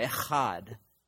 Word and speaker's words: Echad: And then there's Echad. Again, if you say it right, you're going to spit on somebody. Echad.